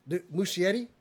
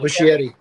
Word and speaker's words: Muschietti: Dwayne Johnson, Andy Muschietti, who you just mentioned. Muschietti? I said that wrong. Anyway. – Muschietti.